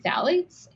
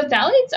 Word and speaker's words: phthalates: Can you tell us what phthalates are? Am I saying that right? – Yeah. So phthalates are like soft, they're in a lot of soft plastics.